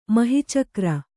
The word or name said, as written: ಮಹೀಚಕ್ರ